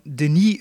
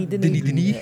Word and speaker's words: Denis: Ja, Enemy van Denis Villeneuve. Ik heb gisteren ontdekt dat het uitgesproken is als, als Denis en niet... Denis.